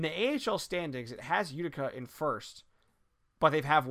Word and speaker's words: Utica: Is it Utica? – Utica. – Right? Yeah, Utica.